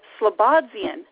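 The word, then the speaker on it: Slobodzien: James Slobodzian. Am I saying it right, James? – Yes, you are.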